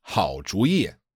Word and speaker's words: 好主意: So usually these two characters are pronounced third tone, fourth tone, right? 主意 means idea. – Yeah. – But when you put the 好 in front of it, it's 好主意.